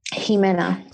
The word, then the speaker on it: Ximena: Ximena. – Perfect. – Easy peasy. – And tell them how to pronounce it again. – Ximena.